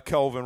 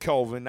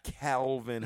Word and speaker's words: Kelvin: And I said Kelvin, not Calvin, how you motherfucker be pronouncing this shit with a C-A. – Calvin got a job. – It's Kelvin. – He actually spells his name. – Hey, you remember that shit? – K-E-L-V-I-N, okay?